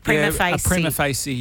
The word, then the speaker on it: prima facie: How do you say it? Prima facie. – Yeah. Yep.